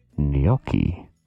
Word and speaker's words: gnocchi: The correct pronunciation is gnocchi. Gnocchi.